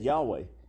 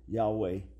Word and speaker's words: Yahweh: Yud-Heh-Vav-Heh in Hebrew, as we pronounce it, Yahweh, today. But again, even the exact pronunciation is under debate.